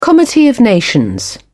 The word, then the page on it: comity of nations: British English